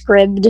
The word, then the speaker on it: Scribd: Um, so the book I'm currently reading is called Without Mercy, and it is by Ava Strong and I'm reading it on the Scribd or Scribed, I think it's Scribd, is how you pronounce it, uh, platform.